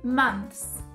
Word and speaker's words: months: E meses em inglês tem aquele TH no final que eu vou te ajudar a suavizar o TH na pronúncia. Então fica assim: months você viu o que, que eu fiz com a língua? Você passa entre os lábios. Months. Months.